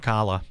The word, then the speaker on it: Ocala: Well, news outlets report that Brian Pritchard of Ocala, or Ocala, I'm not sure how you say it. Ocala.